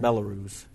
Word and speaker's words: Belarus: I guess that's Belarus, isn't it? That's sure. – How you say that, yeah. Belarus. Yeah, that's how you say it.